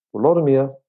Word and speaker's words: Volodymyr: Volodymyr, som jo også er den moderne ukrainske måde at udtale navnet på, Vladimir på moderne russisk, og på dansk altså Valdemar.